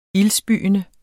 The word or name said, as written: ildspyende